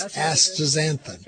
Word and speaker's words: astaxanthin: Well, the X is said like an S. That's why. It's astaxanthin. It okay.